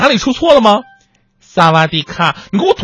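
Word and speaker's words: 萨瓦 迪 卡 (สวัสดีค่ะ): 你好，萨瓦迪卡，就是萨瓦迪是你好的意思，这个卡是不一样的，卡是女人独有的发音，它是一个词缀，女人发音是萨瓦迪卡，男人的词缀是 club，最后一音是要闭合的，所以应该是萨瓦迪 club。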